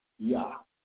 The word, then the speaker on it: Jah: it's going to tell you that it's pronounced Yah.